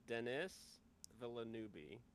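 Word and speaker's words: Denis Villeneuve: I, I believe it's pronounced Dennis Villeneuve.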